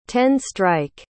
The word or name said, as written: ten-strike